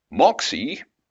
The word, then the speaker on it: MOXIE: MOXIE, the Mars Oxygen In Situ Resource Utilization Experiment, which is apparently an acronym with a silent S, R and U, is on board the Perseverance Mars rover.